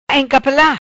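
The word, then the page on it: Encapela: Patua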